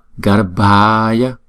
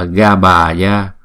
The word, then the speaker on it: garbhaya: Chữ R á, chúng ta phải phát âm cho nghe chữ R nha. Đừng đọc là Gabhaya. Thấy không? Đọc là Garbhaya.